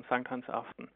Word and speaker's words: Sankt Hans Aften: Und auf Dänisch, jetzt hoffe ich, dass ich es richtig sage, heißt das Sankt Hans Aften? – Das ist genau richtig ausgesprochen, Sankt Hans Aften.